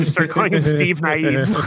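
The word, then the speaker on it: Nieve: It's spelled N-I-V-E, but it's pronounced Naive. He's Steve Naive.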